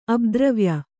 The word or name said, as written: ಅಬ್ದ್ರವ್ಯ